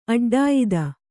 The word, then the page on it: ಅಡ್ಡಾಯುಧ: Kannada